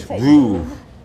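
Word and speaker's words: Veuve: We weren't saying it right. – It's like this. VOOV.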